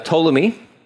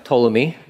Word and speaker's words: Ptolemy: You have Ptolemy. The P is silent. Ptolemy.